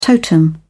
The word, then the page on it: totem: British English